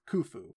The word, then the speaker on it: Khufu: And that is, I looked into how to pronounce it. It's apparently pronounced Khufu.